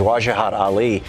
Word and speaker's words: Wajahat Ali: On the line with us is a writer for the New York Times, Wajahat Ali. If I'm pronouncing that right, sir? – That was very impressive. You deserve a Kit Kat for saying it properly.